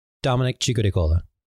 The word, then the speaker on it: Dominic Ciccodicola: How would you say it, Dom? Because I would love to hear Dominic Ciccodicola. – That sounds more natural, but the pronunciation was good.